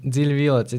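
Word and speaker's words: Dziļi Violets: Dili Violets, it's DZILIVIOLETS. – Okay. – I think I said it right.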